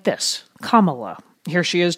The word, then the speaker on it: Kamala: I usually help people pronounce it by saying, well, just think of the hyphen, a comma, and then add a la at the end, and that's it, Kamala.